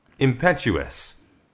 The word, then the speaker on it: impetuous: 명사할 때 impetus예요. 그런데 형용사가 되니까 impetuous가 되고 impetuous.